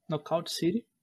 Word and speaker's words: Knockout City: Temos Back for Blood, Knockout City, acho que é assim que se pronuncia. – Knockout. – Knockout City.